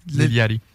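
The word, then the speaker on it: Lil Yachty: Avant, c'était Lil, Yachi. Et un, autre que je me suis. Prononce pas le CH. Ah! – Le CH, Liliati? Liliati.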